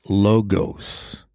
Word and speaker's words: logos: The Greek translation for Word that we read in verse 1 is pronounced logos, which is where we get the verb, the English verb logo.